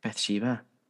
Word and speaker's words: Bathsheba: Bathsheba Sherman. Is that right? – Bathsheba. – That'll do. That's better. That's actually better pronounced.